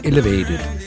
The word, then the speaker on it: ELVTD: Godt nok stave E-L-V-T-D, men udtalt Elevated.